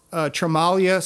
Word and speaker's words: Tremaglia: We will continue to talk to Mark Tremaglia, Silent G, everyone. When you see him on the street, please pronounce his name correctly.